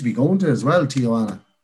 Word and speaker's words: Tijuana: Next one is from Simon Finn, and he said Sean Bannon moving to Tijuana. Did I say it right? – Yeah, Tijuana. I'm nearly sure that is in South America or Mexico or something like that.